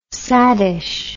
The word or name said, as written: saddish